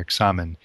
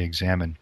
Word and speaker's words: examen: Now, this word examen itself is just the English pronunciation of the Spanish word that St. Ignatius used for this form of prayer, examen, in the Spanish, and we just say examen.